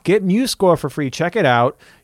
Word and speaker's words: MuseScore: So I'm doubly excited because today is the first time I learned how to say it. I was always like MuseScore. – MuseScore. – But it is, you've corrected me, it's MuseScore.